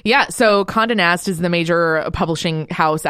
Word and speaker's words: Condé Nast: How do you say this? Is it Cond Nest? – Condé Nast.